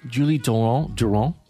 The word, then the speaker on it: Julie Durand: Julie Durand. That's how you say it. It's French, I believe, from the east coast of Canada.